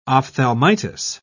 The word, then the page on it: ophthalmitis: British English